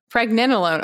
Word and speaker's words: Pregnenolone: Pregnenolone. Did I say that right? – Pregnenolone. – Okay. I knew I was saying that wrong.